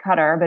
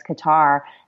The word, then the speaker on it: Qatar: And in Qatar, it's pronounced a little differently, Qatar, but in the U.S., a lot of people know it as Qatar.